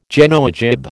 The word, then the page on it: Genoa jib: American English